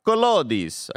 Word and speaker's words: Collodi: It's inspired by Carlo Collodi's novel, or Collodi's, I gotta assume that's how you pronounce it.